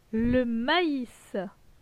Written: Le maïs

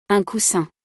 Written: un coussin

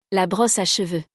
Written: La brosse a cheveu

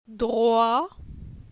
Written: Droi

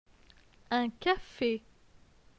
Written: Un cafe

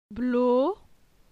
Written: Blo

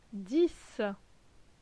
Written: Dix

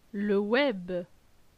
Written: Le web

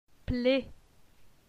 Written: Ple

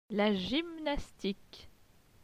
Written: La gymnastique

